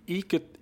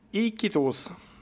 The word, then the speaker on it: Iquitos: Då nådde faktiskt fartyget eh, eh, stad i Peru som heter Iquit, nu ska vi säga, Iquitos. Eh, ja. Uttalar det säkert helt fel.